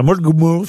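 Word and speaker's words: Mullaghmore: Une bombe télécommandée de 23 kilos a été placée près du moteur de l'embarcation dans une baie irlandaise à Mulgmul. Je sais pas si je prononce bien. – Non, c'est très bien prononcé.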